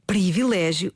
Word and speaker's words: privilégio: Ah, mas antes disso, Filomena, de facto a palavra escreve-se privilégio e deve pronunciar-se em contexto formal, em registro cuidado, de acordo com a norma padrão, privilégio.